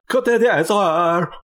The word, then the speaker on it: Côte d'Azur: How do you say that? – Côte d'Azur.